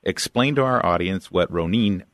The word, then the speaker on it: Ronin: Eh, did I am I pronouncing that right? Ronin. Uh, yeah, we would say Ronin.